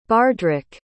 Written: bardrick

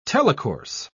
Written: telecourse

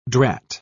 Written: drat

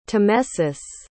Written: tamesis

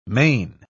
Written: mane

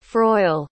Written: froille

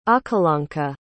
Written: akalanka

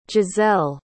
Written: gisselle

